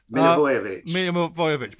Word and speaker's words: Milivojevic: Mija Jovovich. – Wrong. – Oh, come on! I tell you what, actually, if you'd have heard me if you listened to Talk Sport when Alan Brazil tries to pronounce a Greek player, I made Brazil look good trying to pronounce Mija Jovovich. – Milivojevic, which I still can't pronounce now, as you correctly pointed out.